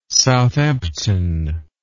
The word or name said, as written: Southampton